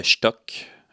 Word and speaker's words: Shtukk: Il s'agit de Stuck. Stuck s'écrit S-H-T-U-K-K.